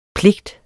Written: PLIGTERNES

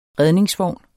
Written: redningsvogn

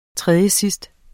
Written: tredjesidst

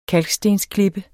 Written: kalkstensklippe

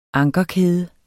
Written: anker

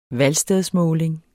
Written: valgstedsmåling

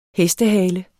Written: HESTEHALEN

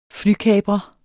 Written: FLYKAPRERE

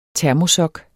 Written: termosok

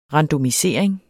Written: randomisering

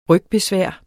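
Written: rygbesvær

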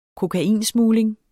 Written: kokainsmugling

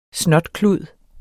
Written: snotklud

